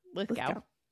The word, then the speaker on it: Lithgow: John Lithgow. How do you say his name? Lithgow? – Lithgow. – Lithgow. Mm-hmm.